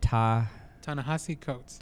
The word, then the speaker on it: Ta-Nehisi Coates: Ta-Nehisi Coates. – Ta-Nehisi Coates